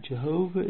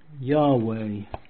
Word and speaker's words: Yahweh: It must be remembered also with reference to the American Committee's recommendation above quoted, that Jehovah is not the pronunciation authorized by the Masoretes. as is unfortunately stated by Dr. Thomas in Phanerosis. But the true pronunciation is probably Yahweh or Yahweh.